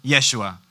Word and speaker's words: Yeshua: Jesus typically spoke Aramaic, so they cut off a syllable, shortened it, Yeshua.